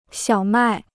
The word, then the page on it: 小麦: xiǎo mài